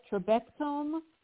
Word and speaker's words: trabectome: But I wanted to mention Dr. Okeke's book, The Building Blocks, and I don't know if I'm pronouncing this right, trabectome surgery. Is that how you say it? – Yeah, that's correct.